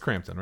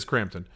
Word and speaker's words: Crampton: Is it Crampton or Campton? It's Crampton, right? I don't want to mispronounce. I follow her on Instagram. If she knew I existed, she'd be upset. It is Crampton.